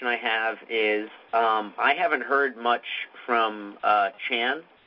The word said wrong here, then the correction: Sean: Sean rhymes with John. Sean.